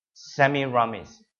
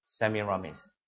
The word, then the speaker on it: Semiramis: Semiramis. You've got to pronounce it correctly, all right? Semiramis.